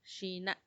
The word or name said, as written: xina